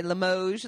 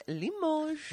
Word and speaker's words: Limoges: We found out we were saying it wrong for the first bit of time. We'd say Limoges. And it was like, no, it's Limoges.